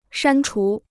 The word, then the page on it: 删除: shān chú